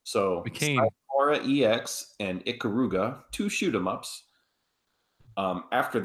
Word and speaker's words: Ikaruga: I believe it's pronounced Ikaruga.